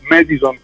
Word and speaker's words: Madison: Le pagine Facebook, Madison Band, Madison in qualunque, diciamo, sempre con la E e non con la A, mm-hmm, perché alle volte uno dice vabbè, Madison americanizzato, diciamo, con tipo in inglese, allora uno dice Madison e si legge Madison. No, Madison con la E, perché Madison sta ad intendere, deriva, diciamo, da Mediterraneo, ecco.